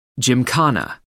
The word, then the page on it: gymkhana: American English